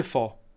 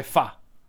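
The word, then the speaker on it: phở: Allora, il, um, nel nord si dice fo. A noi era il fo. Okay. Fo bu. Ok. E, ehm, a Saigon invece era Fe. Ad Ahue l'ho sentito, l'ho sentito pronunciare Fa. Comunque è quello che si scrive PHO, ok?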